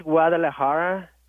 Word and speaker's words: Guadalajara: Yes, it is Guadalajara.